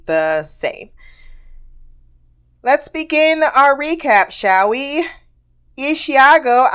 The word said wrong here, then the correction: Ichigo: I want to say that every time, and I know now that it's Ishio. I'm still saying it wrong. Hold on, let me get my pronunciation book out. There we go. Ichigo. There is nothing that lends itself to a nickname with that type of first name.